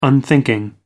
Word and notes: unthinking: American English

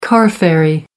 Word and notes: car ferry: American English

